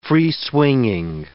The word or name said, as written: free-swinging